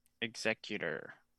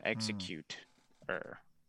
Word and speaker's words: Executor: I would say that's Executor as opposed to Executor. – I think you might be right. I got my emphasis on the wrong syllable.